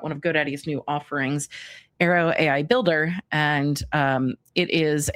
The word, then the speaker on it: Airo: So in making those videos, I needed to pronounce the full name of the product. And I had only said Aero, A-I-R-O. And so I used AI to go back and fix those pronunciations.